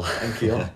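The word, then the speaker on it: N'Keal: Enkil. – Enkil. Like, yeah. – And yeah, the other guys just aren't good. Never have been. – Well, I mean, because the only reason I say his name like that is because he went to Arizona State. And when they played UW, the commentators kept saying Enkil Harry. So that's the only time I'd heard his name.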